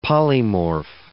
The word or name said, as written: polymorph